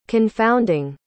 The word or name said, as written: confounding